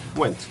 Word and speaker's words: Gwent: Gwent, Gwent, se la pronuncia, Gwent, Bien.